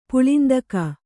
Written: ಪುಳಿಂದಕ

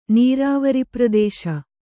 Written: ನೀರಾವರಿಪ್ರದೇಶ

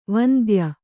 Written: ವಂದ್ಯ

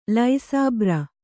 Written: ಲೈಸಾಬ್ರ

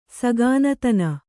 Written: ಸಘಾನತನ

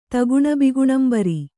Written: ತಗುಣಬಿಗುಣಂಬರಿ

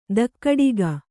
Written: ಧಕ್ಕಡಿಗ

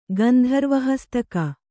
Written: ಗಂಧರ್ವಹಸ್ತಕ

